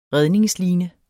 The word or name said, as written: redningsline